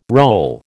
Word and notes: roll: American English

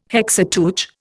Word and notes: Hexateuch: Indian

